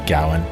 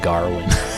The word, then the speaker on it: Gawain: Some people say Gawain. – Gawain. – This movie does something really funny where it kind of plays with that name a lot. Everyone's pronouncing it differently, which is sort of funny because of that. – I love the way the king pronounced it. He calls him Garwin.